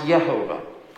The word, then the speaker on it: Jehovah: Get merged together to create the word Jehovah, or probably more properly pronounced Yehovah.